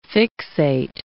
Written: fixate

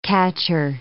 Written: catcher

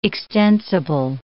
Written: extensible

